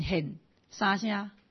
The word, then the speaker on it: 显: 接下来说余丰林，余丰林清显，这显就是旁边再一个王出现的县啊，哎，清显，所以这个声音要读成哎显显显三声，哎，它是剑嘛哈，显显显这是第三声，显显显显的跟这个出现的县同样，因为那个是。